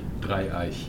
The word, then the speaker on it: Dreieich: Dreieich. – Dreieich.